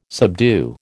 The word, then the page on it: subdue: American English